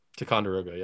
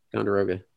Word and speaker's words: Ticonderoga: Ticonderoga, New York. – Ticonderoga, yeah. – Ticonderoga, yeah.